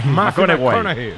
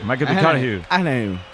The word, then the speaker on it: McConaughey: Me fichó, le gusta que hay que ir corrigiendo la pronunciación. – McConaughey. McConaughey.